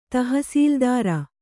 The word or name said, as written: ತಹಸೀಲುದಾರ